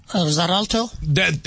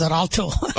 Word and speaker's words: Xarelto: Xarelto? – There we go. See, thank you. X-A-R-E-L-T-O. – I know. They use weird spelling. It's spelled X-E-L-T-O, but it's pronounced as Zeralto.